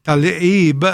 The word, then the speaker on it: Tlaib: But this idea of, and I read something that said that Tlaib is how you actually pronounce it,